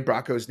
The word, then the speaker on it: Bracco: But I mispronounced Lorraine Bronco's name. I called her Bronco because I had a head cold. And actually, the interesting thing was, I had a hard time saying Lorraine Bracco's name, but then I also really butchered the word comic book.